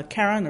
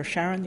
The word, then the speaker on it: Charon: If you first think of uh, the big moon, uh, Charon or Charon, you can pronounce it either way, we're seeing smooth plains on the southern half, which might have been um, the result of uh, early volcanic water flows.